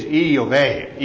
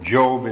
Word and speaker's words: Job: And the Hebrew pronunciation is Iyoveh. So you can pronounce it however you want. Job is the way we normally pronounce it in English.